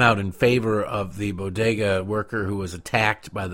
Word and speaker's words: bodega: It's not a complicated word. It is B-O-D-E-G-A. There's no other way to really, well, not normal people for another way to pronounce it. Bo-de-ga. There you go. Syllable, syllable, syllable.